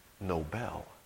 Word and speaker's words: Nobel: Actually, we wouldn't pronounce his name Nobel. We would pronounce it Nobel.